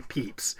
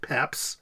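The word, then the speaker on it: Pepys: And everyone who knows about him always is make sure to say Peeps. But the interesting thing that we found was that the family of Peeps have various pronunciations of the name. Here we go again with the inconsistencies. We have some people pronouncing it Peeps, some people pronouncing it Peps, some people Papes, etc. Some people Pepis.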